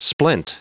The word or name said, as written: splent